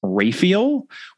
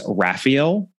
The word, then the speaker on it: Raphael: And I'll just note, as to pronunciation, in the Caribbean Mystery episode, we pronounced Jason Raphael's last name as Raphael, which is how at least one of the adaptations of A Caribbean Mystery pronounced it. It seems that the predominant pronunciation is Raphael. I suppose you could say Raphael as well, but I am going to go ahead and say Raphael anytime I am called upon to say that last name in the course of this episode, which will be many times.